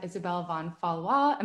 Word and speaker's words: Isabelle von Fallois: I have Isabelle Von Fallois.